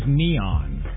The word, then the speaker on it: N3on: I think that you don't know because his name is Neon. – No. It's spelled N, the number 3-O-N. N3on. – Yeah, but he goes by Neon.